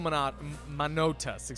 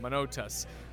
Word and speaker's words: Manotas: Mauro Manotas, excuse me, I mispronounced his name, Manotas.